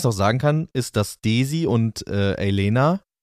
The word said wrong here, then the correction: Alena: jetzt habe ich es wieder falsch ausgesprochen, oder? Wie wird sie richtig? Alena. – Alena. Dass Daisy Alena.